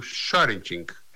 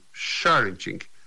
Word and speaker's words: sharenting: Tem sido presença constante nas redes sociais um alerta sobre o sharenting. Isso mesmo, sharenting. Se escreve S-H-A-R-E-N-T-I-N-G. Sharenting.